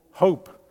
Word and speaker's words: Hoop: And I hear, that your church has recently changed its name to Hop, or Hoop. How do you say it? Hope, which apparently is Plattdeutsch for hope.